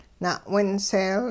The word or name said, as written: na'winseel